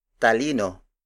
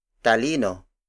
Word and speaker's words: talino: Talino. Talino. Talino is spelled as T A L I N O. It means intellect, the faculty of reasoning and logic, which is different from emotions. Again, the Tagalog word for intellect is talino.